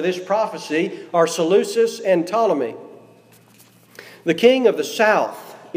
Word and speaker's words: Ptolemy: Not his descendants, not according to his authority, I've listed those generals out: Cassander, Ptolemy, the P is silent, Ptolemy, Antigonus, and Seleucus.